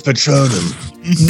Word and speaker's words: Patronum: It's pronounced Patronum.